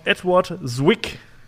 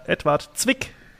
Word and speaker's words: Edward Zwick: Edward Zwick oder Edward Zwick, wenn man ihn mal ganz deutsch aussprechen äh, wollen würde.